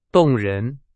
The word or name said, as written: 动人